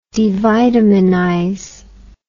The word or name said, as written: devitaminize